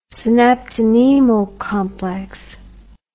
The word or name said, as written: synaptonemal complex